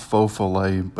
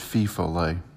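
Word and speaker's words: feu follet: It's pronounced faux-follet, but he said fee-follet.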